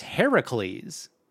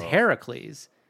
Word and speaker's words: Heracles: Hercules is the Roman pronunciation. – It's Hercules. – It's Heracles. – Oh. – His name is Heracles.